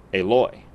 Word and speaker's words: Eloy: So you stress Eloy. – The second syllable. Eloy.